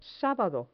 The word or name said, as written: sabado